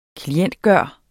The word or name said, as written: klientgjort